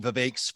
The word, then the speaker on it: Vivek: I got to give you a shout out, by the way, for pronouncing Vivek's first name correctly. You're one of the few people, so you get a big thumbs up on that one. It's Vivek like cake, as he likes to tell people.